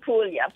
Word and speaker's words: Puglia: And how do you pronounce it? Puglia? – In Puglia. – Puglia.